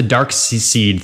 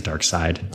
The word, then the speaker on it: Darkseid: I think it's Darkseid. Okay. Seed? Anyways, I don't know. I'll interchange it. It's spelled Seed. It's pronounced Side.